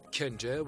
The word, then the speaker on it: Chenje: Chenje, which is pronounced chenje in Azeri Turkish,